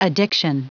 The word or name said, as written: addiction